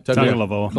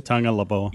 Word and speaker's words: Tagovailoa: But anyway, so Tua Tagovailoa, I never can say his name. Is that right? – Tagovailoa. – Tagovailoa, is that pretty good? – Tagovailoa.